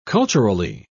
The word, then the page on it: culturally: British English